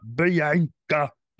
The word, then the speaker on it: Bianca: That's not how you say it. It's said Bianca, not Bianca.